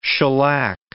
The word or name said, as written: shellac